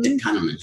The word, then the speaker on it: Kahneman: The other one is Thinking Fast and Slow by Daniel Kahneman. – Kahneman. – Yeah. I was like, I'm not going to say this right.